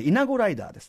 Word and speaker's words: 175R: ってことでね、はい、 175R と書いて、イナゴライダーです、えー、イナゴライダーとの最初の出会いは学生時代で雑誌の紙面でした。